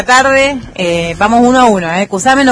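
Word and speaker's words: Cushamen: Yo, para mí, es Cusamen, como se escribe. Muchos le dicen cuyamen, otros dicen o sea, yo, para mí, es cusamen.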